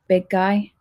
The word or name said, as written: big guy